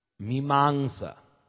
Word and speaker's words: Mimamsa: Well, the way I spell it, M-E-E-M-A-N-G-S-A. But that's my way of spelling it. Mimamsa. Because it's actually M, long I, which is pronounced E. So I write it out as M-E-E because I do things as it sounds.